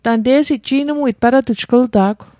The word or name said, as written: ji'nmuit